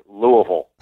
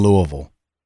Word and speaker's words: Louisville: Well, I've tried to master the local pronunciation, and to the best of my ability, it is Louisville. – Exactly. – You got to roll the tongue, yep. Okay? – There is no Louis in Louisville.